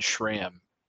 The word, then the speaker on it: SRAM: And I think I butchered that. I think I said SRAM. SRAM. – Yes. – SRAM.